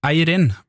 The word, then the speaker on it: Eirin: En gang ble jeg korrigert i uttalen av navnet Eirin, fordi jeg hadde trykk på siste stavelse. Siden hun kom fra Kristiansund, så mener hun at jeg skulle si Eirin med trykk på første stavelse.